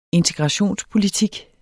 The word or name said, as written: integrationspolitik